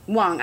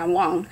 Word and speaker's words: Wang: And, like, there was a moment when I was at TIFF, there was this conference, this seminar that Lulu Wang, actually, I'm pronouncing it wrong, Wang,